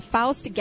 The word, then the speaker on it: Faust: Well, what, again, and where can people find out more information about Faust Galleries? And that's F-A-U-S-T.